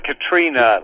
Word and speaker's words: Catriona: And is it Catriona? Did I pronounce your wife's name correctly? – It's pretty close. More like Katrina, like the person.